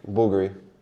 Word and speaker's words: Bulgari: I would say Bulgari, but pronounced it with a certain flair. – Go on. – Bulgari. – Yeah, it's more Bulgari, yeah.